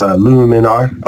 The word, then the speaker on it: Luminar: I'm going to go out and call on MacFund's new program called Lumiere. I never can say it right. It's Luminar.